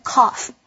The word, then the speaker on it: cough: 咳嗽的动词就是 cough. 请留意一下句尾的 gh 发的是 f 的音，念成 cough.